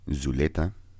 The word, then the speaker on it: Zvleta: All right, so next we're going to talk about Zuleta. And it's spelled Z-V-L-E-T-A. The V stylized for the U.